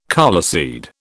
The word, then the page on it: coleseed: British English